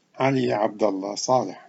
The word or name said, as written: Ali Abdullah Saleh